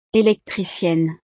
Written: electricienne

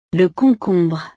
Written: le concombre

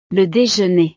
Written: le dejeuner

